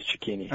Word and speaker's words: Cecchini: I believe it is Cecchini. – All right, well, yeah, let's stay with Cecchini uh, because that is the proper uh, Italian pronunciation.